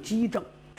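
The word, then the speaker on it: 奇正: 但是呢，有些兵家认为在这儿读就得读成机正，这个棋念机。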